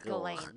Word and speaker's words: Ghislaine: It's Ghislaine. She's all French and shit. I think it's pronounced Ghislaine.